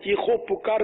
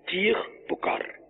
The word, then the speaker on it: چیخ پکار: جیسے ہمارے یہاں چیخو پکار بولتے ہیں, چیخو پکار بھی اہل لغت کے نزدیک ہے کہ واو یہاں آئے گا نہیں, بلکہ چیخ پکار دونوں کے ایک ہی معنی ہے نا, چیخ پکار یہ لفظ تلفظ درست ہوگا.